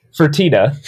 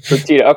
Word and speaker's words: Fertitta: Fertitta. – Fertitta.